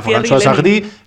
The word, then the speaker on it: François Sardy: Lo mismo se llama François Sardy. Y digo, he dicho yo, muy bien pronunciado. – Pues sí, tío, ha sonado muy bien, muy francés.